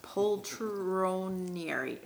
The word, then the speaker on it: Poltronieri: Well, and then finally we added Brandon Politrinieri. What? That's a perfect pronunciation. I appreciate it. – No, it, I'm just laughing because I don't have any idea how to pronounce his last name. That's very good, actually, John. Poltronieri.